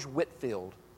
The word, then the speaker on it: Whitefield: Have you ever heard the name George Whitefield? Spelled like Whitefield.